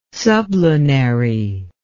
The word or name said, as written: sublunary